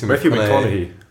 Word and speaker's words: Matthew McConaughey: Matthew McConaughey.